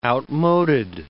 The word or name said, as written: outmoded